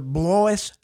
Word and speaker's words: Blois: half as good a Secretary of State as my father Blois Ziegler. It rhymes with voice.